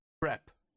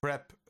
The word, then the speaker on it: Crêpe: Crêpe. Crêpe. Darauf ist die Betonung liegt auf Crêpe.